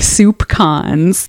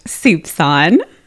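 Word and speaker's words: soupçon: And in an early iteration of in our first iteration of Paranoid Style, when we were completely extemporaneous, and it really showed, I used the word soup con, which I later found out is pronounced soupson.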